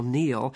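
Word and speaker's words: Kneale: This podcast concerns the English science fiction writer, and especially writer for television, Nigel Neal. That's spelled K as in Kappa, N as in Nero, E-A-L-E, Nigel Neal.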